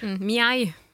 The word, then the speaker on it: meg: Mjei. Uttales meg.